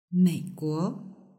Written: meiguo

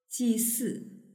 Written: jisi

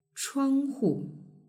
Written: chuanghu

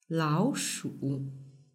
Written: laoshu

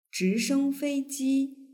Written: zhishengfeiji

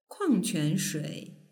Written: kuangquanshui

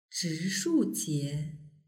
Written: zhishujie

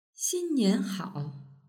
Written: xinnianhao

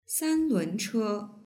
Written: sanlunche

